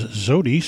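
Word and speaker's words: Xodi: Preceding that was Zodi, spelled with an X,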